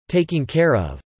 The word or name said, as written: taking care of